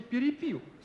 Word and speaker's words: перепил: В-четвертых, просто замечательная рифма «перепел перепил» с переносом ударения. Можно, конечно, в глаголе «перепил», воспользовавшись диалектным произношением, поставить ударение на первом слове.